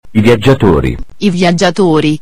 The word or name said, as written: I Viaggiatori